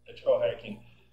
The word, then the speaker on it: ethical hacking: É assim que se pronuncia mesmo? É assim mesmo. Ethical hacking.